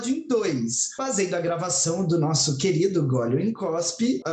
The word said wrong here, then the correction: Engole ou Cospe: uh, ah, falei errado, né? Engole ou cospe?